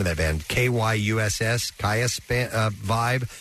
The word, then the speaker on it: Kyuss: Kayas.